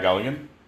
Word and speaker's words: Galligan: Pat Galligan.